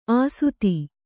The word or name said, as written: ಆಸುತಿ